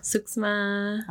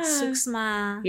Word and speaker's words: suksma: Suksma. – Oh. – Yeah. – Suksma.